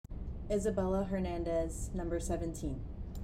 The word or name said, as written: Isabella Hernandez